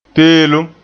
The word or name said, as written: peelem